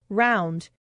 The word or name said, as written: round